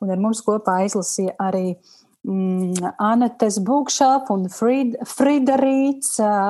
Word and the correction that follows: Frīda Rīts: Un, oh, slikti izrunāju kaut kā šo, šo nosaukumu. Frīda Rīts. Frīda Rīts.